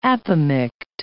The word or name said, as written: apomict